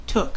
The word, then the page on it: took: American English